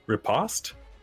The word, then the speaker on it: riposte: So I'm going to use my reaction to riposte.